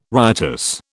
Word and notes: riotous: British English

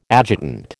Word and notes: adjutant: American English

